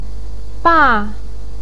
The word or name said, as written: ba4